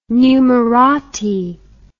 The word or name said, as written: numerati